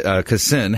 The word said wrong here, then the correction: delicatessen: Uh, How do you pronounce that again? – Delicatessen.